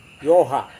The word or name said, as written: yoha